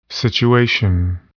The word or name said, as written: situation